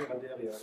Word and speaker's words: Randeria: Ähm, ich denke, für die Geschichtswissenschaft war der Sammelband von ähm, äh, Sebastian Konrad und ähm, Shalini Banderia ähm, jenseits des Eurozentrismus. Ähm, Banderia spricht man sie wahrscheinlich. – Äh, Shalini Randeria, Banderia, genau.